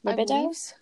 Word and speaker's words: libido: Now, is that how you say it? Libido? That's a cool word. – Libido. But that's basically, like, your sex hormone or your sex drive. – Is it libido? – Oh, yes.